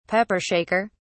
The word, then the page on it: pepper shaker: British English